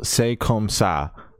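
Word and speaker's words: C'est Comme Ça: Paramore dropped a new single, "Say Comme Ça." I think is how they pronounce it in the song.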